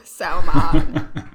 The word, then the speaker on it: salmon: Like, every time I hear someone say salmon, I'm like, stop being obtuse. Like, just quit. You don't pronounce the L.